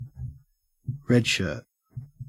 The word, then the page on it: redshirt: Australian English